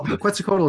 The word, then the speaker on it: Quetzalcoatl: But what we got for Q, and Q is short for Quetzalcoatl, by the way. – Well, well done for pronouncing that. – Yeah, well, Quetzalcoatl is easier for me to pronounce because I've been pronouncing all my life.